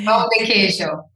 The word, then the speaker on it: pão de queijo: You're going to have to pronounce it for me again. – Pão de queijo.